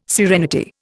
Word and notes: serenity: Indian

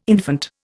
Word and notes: infant: Indian

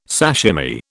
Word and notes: sashimi: British English

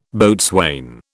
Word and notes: boatswain: British English

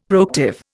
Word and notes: proactive: Indian

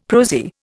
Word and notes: prosy: Indian

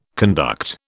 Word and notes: conduct: British English

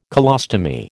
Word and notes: colostomy: American English

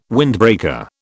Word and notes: windbreaker: British English

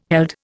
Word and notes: held: Indian

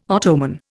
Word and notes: Ottoman: Indian

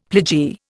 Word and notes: pledgee: Indian

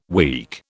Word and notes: weak: British English

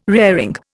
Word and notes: raring: Indian

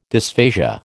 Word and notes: dysphasia: American English

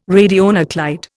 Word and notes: radionuclide: Indian

